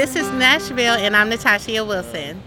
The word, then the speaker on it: Nashville: Supposed to be two syllables. This is Nashville. You can run it together. Nashville.